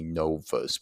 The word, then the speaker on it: novus: It comes from the Latin novus, or sounded out or pronounced phonetically, it would be novus. But remember, V's are pronounced as W's in Latin.